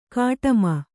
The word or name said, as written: ಗುಡುಮಿ